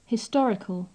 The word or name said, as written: historical